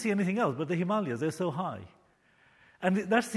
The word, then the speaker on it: Himalayas: I was visiting some friends in Nepal, and they took me to the correct pronunciation is Himalayas.